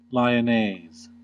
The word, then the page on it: lyonnaise: American English